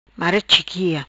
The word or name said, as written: Mara Chiquia